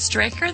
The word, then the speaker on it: Straker: Our guest today is David Straker. That is S-T-R-A-K-E-R.